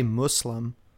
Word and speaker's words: Muslim: And he keeps saying Muslims. That's not the right... That's not how you say it. – Actually, most of the time, I think when I've heard, like Middle Easterners, when they say it, they say Muslim. Like Muslim.